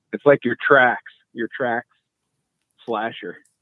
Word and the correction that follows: Traxxas: Okay, I like the name Slasher, but yeah, you gotta say it right. Traxxas. I even know that. I'm not even an RC and I know it's Traxxas.